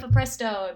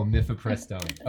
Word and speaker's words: mifepristone: mifepristone. Oh, mifepristone. okay, that's right. Um, mifepristone. Right. So mifepristone